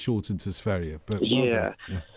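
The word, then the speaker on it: Sverige: No, Sveria is the right, correct pronunciation. Well done, you, Ruby. Yeah, it's Sveria.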